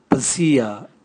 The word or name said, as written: bwasia